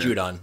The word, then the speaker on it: Judon: And earlier in the game, uh, uh, how do you say it? Judon? Juden? Yes, Judon. Judon, yeah. Judon.